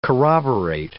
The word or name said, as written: corroborate